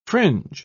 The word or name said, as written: fringe